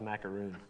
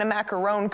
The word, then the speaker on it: macaron: I thought it was macaroon. – There's a difference. There's a macaroon cookie and a macaron cookie.